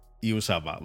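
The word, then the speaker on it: Uzomah: Y digo, joder, yo es que, es que yo les oigo decir Uzama. Y, y Uzoma, vale, Uzoma. Y este fin de semana me, puse y dije, a ver cómo lo dicen. Yusama vale. – Aunque se escribe Uzoma.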